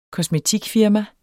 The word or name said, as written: kosmetikfirma